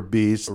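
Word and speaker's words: Bæst: Now it's spelled weird because it's Danish for beast, right? – B-A-E-S-T, but it's you say beast.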